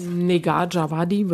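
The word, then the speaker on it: Négar Djavadi: Das nächste Buch spielt auch in Frankreich, heißt Die Arena von Negar oder Nejar. Djawadi, wie spricht man denn richtig aus? – Negar Djawadi, würde ich auch sagen.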